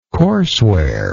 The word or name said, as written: courseware